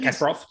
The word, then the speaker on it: Kasparov: Gasparov is, I believe he's Kasparov. Yep.